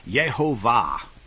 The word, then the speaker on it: Yehovah: So, for example, when I hear people say his name, and the name, you're supposed to pronounce it the way that normal Hebrew says, Yod-Heh-Vav-Heh would be Yehovah.